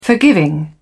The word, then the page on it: forgiving: British English